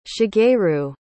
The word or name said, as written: shigeru